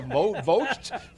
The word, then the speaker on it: Vogt: Yeah, Vogt.